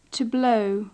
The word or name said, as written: to blow